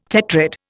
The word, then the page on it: tetrad: Indian